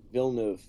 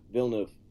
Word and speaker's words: Villeneuve: how do you say his last name? – Villeneuve. – Really? – Yeah, Villeneuve.